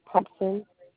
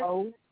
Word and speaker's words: Thompson: Clarissa, C-L-A? – Uh-huh, C-L-A-R-I-S-S-A. – And what's your last name, ma'am? – Thompson, T-H-O. – Thompson? – Thompson.